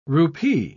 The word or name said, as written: rupee